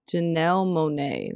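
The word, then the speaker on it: Janelle Monáe: But Janelle Monae, that's how you say her name, right? – Yeah.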